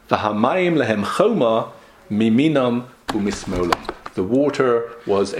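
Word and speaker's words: choma (חומה): It's still pronounced choma, but it's without a vav.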